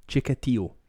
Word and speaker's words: Chikatilo: I looked it up. That's how you say his name. Andrei Chikatilo.